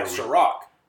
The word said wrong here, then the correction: Ciroc: Ciroc? How do you say it? – Yeah, C-I-R-O-C. Ciroc.